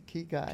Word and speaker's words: Ikigai: Ikigai. – Ikigai.